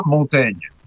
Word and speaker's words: Montaigne: Porque en el francés contemporáneo se pronuncia Montaigne.